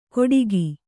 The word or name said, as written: ಕೊಡಿಗಿ